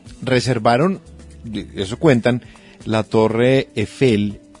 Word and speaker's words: Eiffel: La Torre Eiffel fue... – A mí me pronuncia bien la Torre. La Torre me la pronuncia bien. – ¿Cómo es que es? – Eiffel.